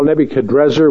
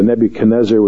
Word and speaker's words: Nebuchadrezzar: Well, first of all, Nebuchadrezzar, which your Bible probably has, is actually probably the more accurate pronunciation, but we're also used to the Nebuchadnezzar, which the Hebraized transliteration leads us to. So I won't try to correct that, although if you're going to pick between the two, the Nebuchadrezzar is actually the, perhaps, scholars believe, maybe the more closer to the Chaldean pronunciation.